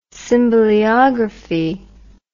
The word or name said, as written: symboleography